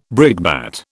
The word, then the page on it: brickbat: British English